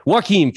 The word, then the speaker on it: Joaquin: Joaquin Phoenix, thank you.